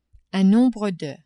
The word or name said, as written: un nombre de